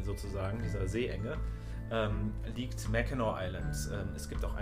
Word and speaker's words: Mackinac: Wird aber beides, wird alles Mackinac ausgesprochen, egal wie es geschrieben wird.